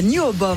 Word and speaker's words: NWOBHM: Pour définir un style de musique, mais en Angleterre, la New Obom, voilà comment on le prononce, fait des émules au sein de cette nouvelle scène rock.